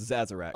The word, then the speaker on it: Sazerac: It's spelled Sazerac, but it's pronounced Zazarac.